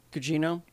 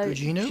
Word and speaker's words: Gugino: Carla Gugino. – Uh, Gugino.